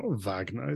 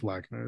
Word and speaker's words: Wagner: I always want to say it like the composer, and it's probably not Wagner, it's Wagner.